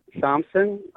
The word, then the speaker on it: Somsan: My name is Michael Somson. It's spelled S-O-M-S-A-N.